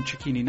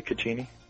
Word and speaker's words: Cecchini: And then uh, another one uh, who's pronouncing his name correctly as an Italian, uh, Garin Garin Cecchini, not his brother Gavin. – Yeah, uh, absolutely, Cecchini.